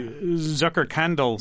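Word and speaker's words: Zuckerkandl: One of the things you write about is the Zucker – we talked about the salons, the Zucker Candle Salon. – I love that. – Did I pronounce that correctly? – You did it very well.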